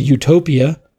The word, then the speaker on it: Eutopia: It's called Utopia, but it's spelled E-U-T-O-P-I-A. So that E-U as in eugenics.